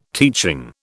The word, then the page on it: teaching: British English